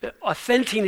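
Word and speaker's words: authentine (αὐθεντεῖν): So it's like Ovaltine, but the transliteration, meaning the English pronunciation of a Greek word, authentine is used one time in the entire New Testament, and it's used by Paul in this situation.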